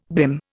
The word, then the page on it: BIM: Indian